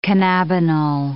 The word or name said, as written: cannabinol